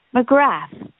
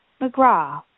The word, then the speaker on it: McGrath: And in many places, this name is pronounced McGrath as a result of English and American influences. Here in Atlantic Canada, where I am from, Irish roots and Celtic connections run deep. In fact, if you are traveling through the Maritime provinces, especially Newfoundland and Labrador, and the Cape Breton region of Nova Scotia, you will almost always hear this name pronounced as McGraw.